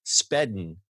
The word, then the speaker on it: Spedn: Flexa has established a network and launched an iOS wallet app called Speden. It's S-P-E-D-N. I swear I didn't mispronounce that.